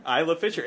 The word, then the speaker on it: Isla Fisher: Isla Fisher. Oh. The S is silent, apparently.